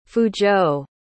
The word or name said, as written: fuzhou